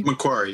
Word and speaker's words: Macquarie: Dr. Campbell completed his doctorate in Greek New Testament and linguistics at, do I pronounce it, Macquarie University? – Macquarie, yeah.